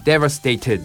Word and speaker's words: devastated: で、あとはよりこう滑らかな発音、突っかからないようにするためにもね、 Devastated で最後の T がこう Ted って出てくると。まあ、イギリス発音とかだったらね、かなり滑舌よく発音するんだけれども、まあ、本州はちょっとアメリカ発音を中心にしているので、この T ね、やっぱり濁らせましょう。母音に挟まれた、ね、 T でございます。